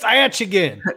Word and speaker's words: Achigan: It's Achigan.